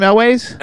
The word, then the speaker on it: Elwes: Is that how you pronounce his last name, Elwes? – Elwes, yeah.